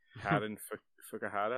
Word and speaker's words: Fukuhara: Karen Fukuhara.